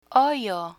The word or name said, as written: hoyo